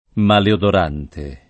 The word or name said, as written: maleodorante